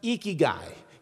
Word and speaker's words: ikigai: It's pronounced ikigai. You lift your cheeks like you're smiling.